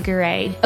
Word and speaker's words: Garay: And her last name is spelled G-A-R-A-Y. So that's Lily, like the flower, and then Gray.